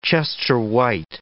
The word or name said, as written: Chester White